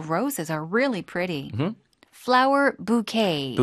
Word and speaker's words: bouquet: 꽃다발이요. 스펠링이 B-O-U-Q-U-E-T-S인데 bouquet. Bouquet. 뭐 우리가 흔히 비슷한 어떤 어감으로 읽는 불어 단어들이 debut라든가 yes. 네. – debut라든가 뭐 buffet. – Yeah. – buffet, ballet, valet 이런 것처럼 뒤에다 강세 주는 단어들이죠.